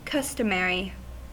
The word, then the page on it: customary: /ˈkʌst(ə)mɹi/